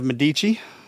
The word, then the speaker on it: Medici: He is the CEO, founder, and president of Medici Telemedicine. Did I say that right? Yes, sir because I,